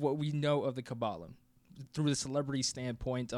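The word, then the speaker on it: Qabbalah: So it's Q-A-B-B-A-L-A-H. – And it's not Kabbalah, right? We say Kabbalah because we're in the United States, but how would you pronounce it? – Kabbalah.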